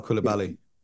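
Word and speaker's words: Coulibaly: Bilal Koulibaly.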